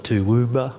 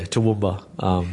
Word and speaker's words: Toowoomba: People call it Toowoomba or Toowoomba.